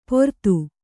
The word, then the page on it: ಪೊರತು: Kannada